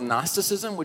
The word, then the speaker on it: Gnosticism: Well, there was this thing in the early church that happened, and there's a thing called Gnosticism, which you're like, what is that? And it's spelled with a G.